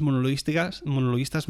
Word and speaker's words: monologuistas: monologuistos, monolistos, monolistas, monolingüistas, lingüística, pronunciación es lo que le falta a este tío. Monologuistas. Tan difícil no es.